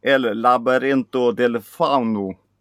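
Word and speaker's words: El laberinto del fauno: Jag har sett El Labyrinto del Fauno. – Perfekt. Perfekt uttal.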